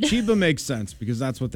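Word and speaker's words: Cheba Hut: Somebody said, it is pronounced Chiba Hut, not Cheba.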